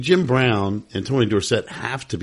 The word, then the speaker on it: Dorsett: who ran at Pitt but added an E to his name later and changed the pronunciation of his last name to Tony Dorsett.